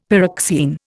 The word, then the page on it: pyroxene: Indian